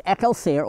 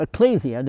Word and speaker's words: ekklesia: Now the word church comes from the Greek, and that word is ekklesia or ecclesia, depending on how you pronounce it, and it means the called out.